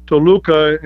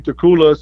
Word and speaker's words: Toluca: And, you know, I actually picked Toluca, and Howard Finkel couldn't pronounce it, so he kept saying Tecula, so that's where Tecula came from.